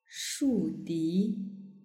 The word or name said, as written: shudi